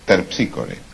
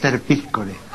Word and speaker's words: Terpsícore: Esther Piscore, dije. – No dije Esther. – ¿Cómo que no dije? – Dije, es Terpsícore. – Claro. Es Terpsícore. Es Terpsícore. Esa es la pronunciación griega, claro.